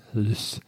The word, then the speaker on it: Lyz: Oktober in Siegen im Lütz oder Lüs, L-Y-Z, wie auch immer man, wie würdest du das aussprechen? – Lüs. Im Lüs.